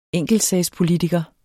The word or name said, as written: enkeltsagspolitiker